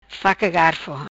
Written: Faca Garfo

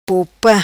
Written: Popa

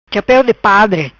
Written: Chapeu Di Padre

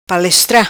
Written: palestra

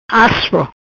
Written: Aspro

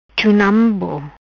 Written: Chunambo

